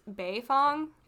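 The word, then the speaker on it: Baefong: My name is Beifong, like Toph Beifong, spelled with Bei. – Lin Beifong. – As in, spelled B-A-E.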